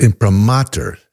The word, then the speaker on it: imprimatur: No, it's imprimatur, accent on the third syllable.